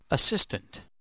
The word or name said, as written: assistant